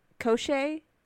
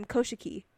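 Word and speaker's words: Koschei: But Koshei, that's his name? – Yeah. – It was not right.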